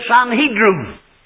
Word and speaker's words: Sanhedrin: That's an old Hebrew word, and it can be spelt with an M at the end or an N at the end, so don't be picking holes in it. You can say Sanhedrin or Sanhedrin, whatever you like. It's spelt both ways.